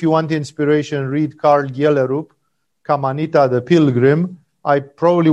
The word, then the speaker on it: Gjellerup: No, if you if you want an entertainment book to read, Karl Gjellerup. It's written G J E That's how Danes pronounce Gjellerup.